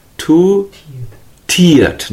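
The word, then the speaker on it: two-tiered: Too tired, not tired. Tired means sleepy.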